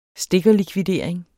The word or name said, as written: stikkerlikvidering